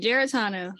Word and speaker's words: Giaratano: You got it right, Giaratano.